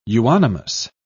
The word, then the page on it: euonymus: British English